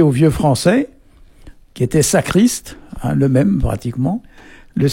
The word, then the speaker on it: sakrist: Alors ça s'écrit S-A-K-R-I-S-T. Et ici, on prononce sacriste, hein. Le S ici, il y a toujours, souvent tendance à donner un Z, quoi.